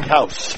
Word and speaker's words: Couse: That was Chase, and how do you pronounce his last name? – House. – House, okay. – C-O-U-S-E yep.